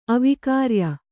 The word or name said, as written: ಅವಿಕಾರ್ಯ